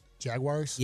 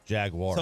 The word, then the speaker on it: Jaguars: I think you're saying their team name wrong. – Jaguars? – Yes. Yeah. I think it's War. – Jaguars.